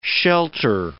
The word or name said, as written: shelter